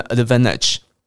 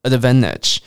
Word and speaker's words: advantage: 那有些人会把尾巴的那个 tage 的 t 消音，诶，有些人会念成 advantage。好，消音就可以让你念得更放松，然后更快，advantage。 Advantage，那英国人遇到 v a n 有时候会念成 von 这种声音，所以有时候你会听到英国人念 advantage，advantage。 OK，挑一个你喜欢的来念 advantage，advantage，advantage。